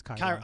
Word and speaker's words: Chiron: I think it's Chiron. – I'm gonna go with Chiron. You're definitely right.